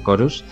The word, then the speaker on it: Chorus: También vimos eh, Corus Chorus, porque supongo que se pronuncia Chorus. Sí. Chorus.